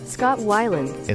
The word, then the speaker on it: Weiland: Weiland or Weiland? I know these guys, but I want to make sure. And so we're going to go back to the Pronouncify lady, Scott Weiland. – Scott Weiland. – It's Scott Weiland.